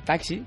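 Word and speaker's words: Taksee: Taxi, que se escribe T-A-K-S-E-E, e, es la aplicación de moda.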